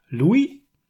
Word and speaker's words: Louis: Also der Vorname ist nicht Louis, sondern Louis tatsächlich. De, da ich denke, das ist wenig überraschend. Der Nachname wird Funès ausgesprochen. Also Louis de Funès.